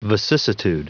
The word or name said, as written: vicissitude